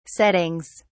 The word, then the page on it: settings: British English